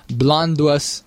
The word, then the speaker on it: Blönduós: The farmers locked themselves and their children in a farmhouse north of the town Blönduós in Iceland. – Mm. – That's probably not the way to pronounce that name. – No, that's it.